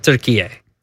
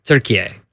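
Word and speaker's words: Türkiye: They pronounce it Turkiye. – Turkiye. – It's pronounced Turkiye.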